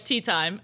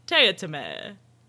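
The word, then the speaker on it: Teatime: So the assassin dude gives the job to one of his assassin peeps, who is named Mr. Teotime, which is spelled like tea time, and he hates when people call him Mr. Tea Time because it is pronounced Teotime, I think.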